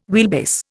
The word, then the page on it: wheelbase: Indian